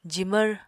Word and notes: jimvr: apatani

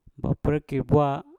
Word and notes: baprekkir bwa: mpur

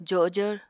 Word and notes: jojvr: apatani

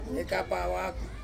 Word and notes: kapa awaku: Ansus

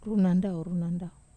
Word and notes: ru nandau: wamesa

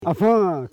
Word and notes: afan: a.fan

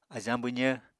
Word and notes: ajañ-bwzv: Apatani

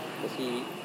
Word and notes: kosiri: Serewen

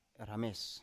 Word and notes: rames: Biak

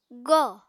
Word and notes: góò: apatani

